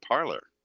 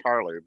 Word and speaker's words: Parler: Speaking of, poor thing over at Parler. I understand that they want it pronounced Parler because Parler sounds too French.